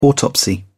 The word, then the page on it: autopsy: British English